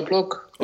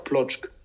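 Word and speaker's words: Płock: Also ich vertraue dir jetzt, ich habe keinen Plan selber, wie man die, spricht man die so aus? Ist das Plock? – Wisla Plock, ja. Okay, ja, gut. Ja, du, ich, wie gesagt, bin... – Vielleicht auch Plotsch.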